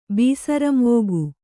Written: ಬೀಸರಂವೋಗು

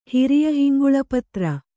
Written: ಹಿರಿಯಹಿಂಗುಳಪತ್ರ

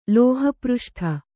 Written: ಲೋಹಪೃಷ್ಠ

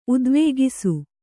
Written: ಉದ್ವೇಗಿಸು

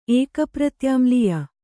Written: ಏಕಪ್ರತ್ಯಾಮ್ಲೀಯ

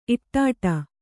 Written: ಇಟ್ಟಾಡು

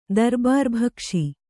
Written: ದರ್ಬಾರ್‍ಭಕ್ಷಿ

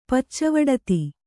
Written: ಪಚ್ಚವಡತಿ

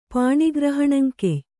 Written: ಪಾಣಿಗ್ರಹಣಂಕೆಯ್

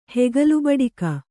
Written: ಹೆಗಲುಬಡಿಕ

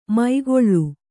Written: ಮೈಗೊಳ್ಳು

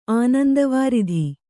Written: ಆನಂದವಾರಿಧಿ

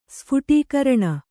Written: ಸ್ಫುಟೀಕರಣ

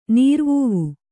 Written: ನೀರುವಾವು

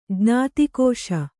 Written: ಜ್ಞಾತಿಕೋಶ

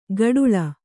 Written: ಗಡುಳ

